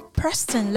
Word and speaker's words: Preston: They're from Preston, Lancashire. Is that right? My pronunciation right? – Yeah. Very good.